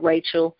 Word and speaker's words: Rachel: It's spelled like Rachel, R-A-C-H-E-L, last name Wright, W-R-I-G-H-T.